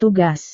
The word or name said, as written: tugas